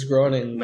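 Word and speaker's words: Groening: Damn, but like imagine the shit that the Simpsons is gonna go on when Matt Gorgon dies. – Gorgon. Groning? – Gorgon? – It's Groning. – Matt Gorgon? – Groning.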